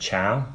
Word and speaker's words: Chao: I think you pronounce it chao, C-H-A-O, chao.